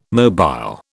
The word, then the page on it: mobile: British English